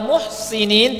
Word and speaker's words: muhsinin: Kalau muhsinin pakai sad artinya adalah orang yang menjaga kehormatan. Ada di Quran, dua-duanya ada, ya. Dan itu kita mesti bacanya dengan tepat, ya. Innahum kanu, ya. Innahum kanu qabla dhalika muhsinin pakai sin. Kemudian ada ayat yang lain baca muhsinina ghayra musafihin. Itu beda antara sin dengan sad.